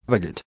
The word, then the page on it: baguette: American English